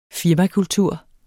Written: firmakultur

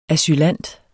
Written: asylant

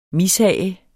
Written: mishag

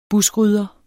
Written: buskrydder

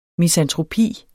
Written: misantropi

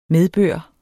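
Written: MEDBØR

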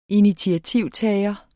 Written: initiativtager

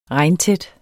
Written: regntæt

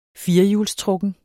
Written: firehjulstrukken

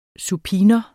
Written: supinum

